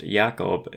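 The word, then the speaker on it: Yaakov (יעקב): Jacob, pronounced Yaakov in Hebrew, means to take, to supplant, or to deceive.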